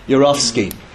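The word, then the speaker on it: Yourofsky: That's spelled G-A-R-Y. Your as in your, Y-O-U-R. Off as in of, O-F. S-K-Y as in sky, yeah? Yorsky.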